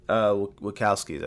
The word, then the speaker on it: Wachowski: The Wachowski brothers, am I saying that right? – Wachowskis, I think. – Wachowski, excuse me.